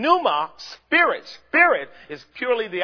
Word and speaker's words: pneuma: The word ghost is an English term that does not correspond with the Greek phrase pneuma or pneuma. Sometimes the P is silent.